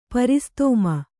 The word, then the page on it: ಪರಿಸ್ತೋಮ: paristōma; kannada